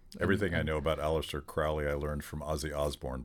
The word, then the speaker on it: Crowley: Apparently, he pronounced it Crowley, rhymes with holy.